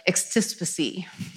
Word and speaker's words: extispicy: And that's this practice of extispicy. It's spelled like extra spicy, and it's really hard for me not to say it like that.